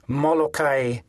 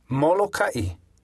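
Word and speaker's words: Molokai (Molokaʻi): For example, in the two island names often mispronounced as Molokai and Lanai, there should actually be okina. They should be pronounced Molokai and Lanai. Say them after me. Molokai.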